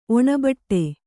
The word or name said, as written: ಒಣಬಟ್ಟೆ